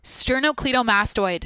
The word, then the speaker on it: sternocleidomastoid: I don't even think I'm saying that right. Sternocleidomastoid. Sterno. Each of a pair of long muscles that connect the sternum, clavicle, and mastoid process of the temporal bone and serve to turn and nod the head. I said it wrong, but I was on track there.